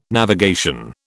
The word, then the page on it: navigation: British English